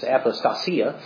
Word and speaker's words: apostasia: The word translated here, falling away, is apostasia. Actually, I put the emphasis on the wrong syllable there. It's apostasia, from which we get the words in English apostasy and apostate.